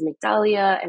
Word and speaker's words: Migdalia: My full name is Migdalia, and I would always get a lot of different mistakes of pronunciation or enunciations of it.